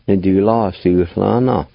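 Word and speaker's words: Nidulasulvna: Again, there's a faint H sound before the L in this word. Pronounced like this. Ni, du, la, su, la, na. Ni, du, la, su, la, na.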